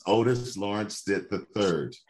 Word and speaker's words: Otis Lawrence Stitt the third: And yes, you did get my name wrong. It's Otis Lawrence Stitt the third.